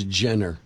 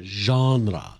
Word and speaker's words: genre: They put macabre as macabre, and they put genre as Jenner. Genre.